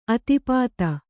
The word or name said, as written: ಆತಪ್ತ